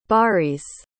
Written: baris

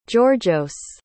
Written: georgios

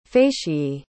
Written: facie

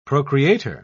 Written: procreator

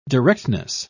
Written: directness

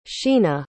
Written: sheena

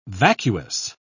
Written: vacuous

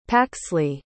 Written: Paxley

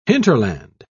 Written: hinterland

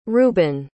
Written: reuben

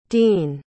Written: deane

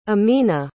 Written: ameenah

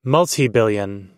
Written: multibillion

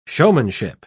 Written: showmanship

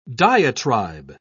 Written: diatribe

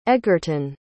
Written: egerton

